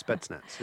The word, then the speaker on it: Spetsnaz: Yes, Spetsnaz.